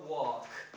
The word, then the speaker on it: walk: It is not talk or walk. Yes? Did you know that? Did you know that? No. Of course. So, or walk. Okay? The L is silent.